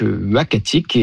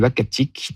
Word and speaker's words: Uhackatic: Alors, le nom, ça sera donc Uhacatic. Et ce nom-là, donc, il s'écrit U-H-A-C-K-A-T-I-C, nous a été proposé par le conseil des Inuits d'Ekunitchit, qu'on a contacté justement pour avoir leur avis.